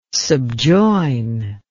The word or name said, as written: subjoin